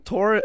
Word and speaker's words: Tor: And also my name, like Tor, is also a bit, it's like in Norwegian, it's Tor. Tor. Tor.